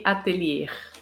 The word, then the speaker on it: Atelier: Goldone Atelier, eu falei errado, né? – Eu é, falei... Atelier, com um R no final.